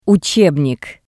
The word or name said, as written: utsebnik